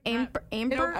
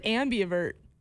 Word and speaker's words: ambivert: There's no, you said per, and that sounds like pervert, and that's not it. – Wait, how do you say it? – I think it's ambivert. It's like A-M-B-I-V-E-R-T.